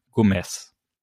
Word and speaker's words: Gomez: Und eigentlich, also auch so wie er geschrieben würde, müsste es Gomez sein, also mit Z am Ende und dieses Z wird aber wie ein hartes S ausgesprochen. Also ich selber äh, habe nie wirklich Spanisch gelernt, aber das hoffe ich, kriege ich richtig hin. Gomez. Ähm, allerdings mit Betonung auf dem O.